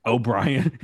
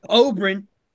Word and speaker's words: Oberyn: How'd I say it? – O'Brien. – Did I really say it like that? – Yeah.